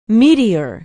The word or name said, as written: meteor